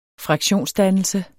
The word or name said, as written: fraktionsdannelse